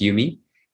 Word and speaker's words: UM2II: So we have an acronym, UMI, UM2II, pronounced UMI.